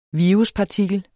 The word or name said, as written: viruspartikel